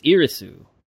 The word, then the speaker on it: Iris: Well, the Japanese pronunciation is Irisu.